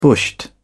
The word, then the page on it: bushed: British English